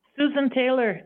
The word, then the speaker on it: Susan Taylor: Susan Taylor, S-U-S-A-N-T-A-Y-L-O-R.